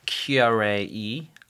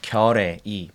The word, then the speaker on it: Keyarae Yi: Start with the perfect pronunciation of your name. – Oh, yeah. – So we can help everybody practice. – Okay. My name is Keyarae Yi. I know it's really hard to pronounce. You know, I can, you know, let you know my exactly name is, you know, Keyarae Yi.